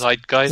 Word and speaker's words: Zeitgeist: How do you say that word? Zeitgeist or Zeitgeist? – Zeitgeist. – Zeitgeist, thank you.